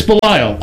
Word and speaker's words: Belial: Yeah, I looked it up, and it's Belial.